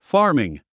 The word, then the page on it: farming: fάː r miŋ; ファーミン